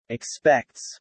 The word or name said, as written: expects